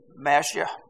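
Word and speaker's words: Mashiach (משיח): It's Mashiach.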